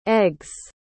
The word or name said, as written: eggs